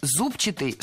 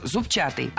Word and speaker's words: зубчатый: Мы услышали прилагательное «зубчатый» с ударением на первом слоге. И это правильный вариант. Но правильным он стал совсем недавно. Раньше нужно было ударять второй слог «зубчатый».